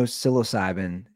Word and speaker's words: psilocybin: She goes, well, there's no psilocybin.